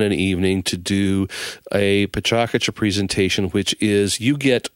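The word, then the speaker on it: Pecha Kucha: It looks like it says Pechacucha, but it's pronounced Pachacucha, which is Japanese for chit-chat.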